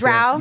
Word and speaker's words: drow: Oh, It's drow. You've been saying it wrong.